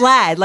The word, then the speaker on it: LAD: I always call them LA. I always thought they were out of LA. – Oh, really? It's Lad, like a British term for like a British guy. Lad.